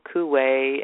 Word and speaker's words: Coué: Now, there was a gentleman by the name of Emil Coué, and you can Google him.